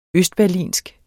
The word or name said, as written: berlinsk